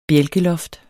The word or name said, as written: bjælkeloft